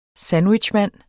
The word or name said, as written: SANDWICHMAND